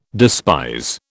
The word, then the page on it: despise: British English